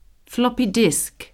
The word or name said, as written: floppy disk